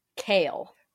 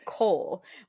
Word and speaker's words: Chaol: Because in Throne of Glass, there is a character, and his name is spelled C-H-A-O-L. And Nicole told me that you're supposed to pronounce that name Kale. In my head before, I had been pronouncing it Cole, which I like a lot better because, so I was like, I'm still going to pronounce it Cole in my head.